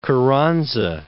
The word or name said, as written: Carranza